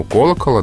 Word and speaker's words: колокола: В единственном числе ударение остается на корне, у колокола, да, колоколу, с колоколом. А во множественном числе ударение, начиная прям с именительного падежа, переходит на окончание.